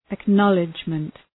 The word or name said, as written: acknowledgement